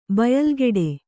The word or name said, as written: ಬಯಲ್ಕಡೆ